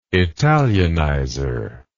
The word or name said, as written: italianizer